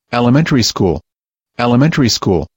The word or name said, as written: elementary school